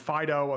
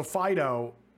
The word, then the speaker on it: Fidough: Also, Fido, dog, but it also looks like bread, and it's spelled F-I-D-O-U-G-H, like dough, like bread dough.